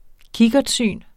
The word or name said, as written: kikkertsyn